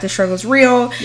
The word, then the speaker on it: The Struggle's Real: And follow us on our Instagram, our podcast Instagram, at The Struggle Real. – Wait, yeah. – Did I say that right? – Yeah. So it's follow us on our podcast Instagram at The Struggle's Real.